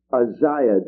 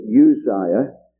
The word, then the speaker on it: Uzziah: In the year that King Uzziah died, I know most of my brethren pronounce it Uzziah, but it could only have one Z to pronounce it Uzziah. Because there are two Z's or Z's, you must say Uzziah. That's what they tell me, those who know best.